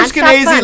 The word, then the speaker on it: Skenazy: We had Lenore Skenazy live in studio, rhymes with crazy.